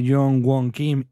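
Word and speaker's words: Kim Young-gwon: Y esos goles de Jong Won Kim y de Hee Chang-wan, ¿los he pronunciado bien? – Los has pronunciado bien, pero es lo que te dije el otro día.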